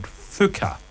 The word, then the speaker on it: Fuuka: because 2 weeks ago they added Fuka from Persona 3. – You pronounced it right. – Right. listen, the spelling is F-U-U-K-A, okay?